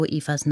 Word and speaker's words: Eva: »Du musst Eufe sein«, hat er am Flughafen gesagt. Und sie hat ihn nur böse angeguckt und langsam den Kopf geschüttelt und »Eva« gesagt, wieder und wieder. Jedes Mal hat sie die erste Silbe noch ein wenig länger gedehnt und mit ihrem breitgezogenen Mund ausgesehen, als würde sie lächeln.